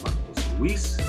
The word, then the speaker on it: Marcos Luis: One Mike Knight is spelled O-N-E-M-I-C-N-I-T-E. And you can follow me at Marcos Luis, M-A-R-C-O-S-L-U-I-S, on all social media too.